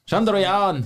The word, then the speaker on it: Chandrayaan: Chandrayaan 3 has landed successfully on the surface of the moon.